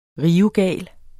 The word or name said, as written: rivegal